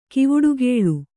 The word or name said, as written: ಕವಡುಗೊಲೆ